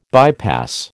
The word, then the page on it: bypass: American English